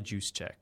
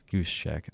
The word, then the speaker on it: Juszczyk: Yeah, and how do you say the fullback's name? And Kyle Juszczyk. – Juszczyk, okay. So, yeah, he's a guy that I've seen almost every week kind of factor into the passing game as well.